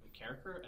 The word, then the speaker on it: McCarricker: My last name is McCarricker as McCracker. – Oh, jeez. – Like, not even the right order of letters. And it's totally unrelated people. People from different cities, different countries have never met McCracker every single time.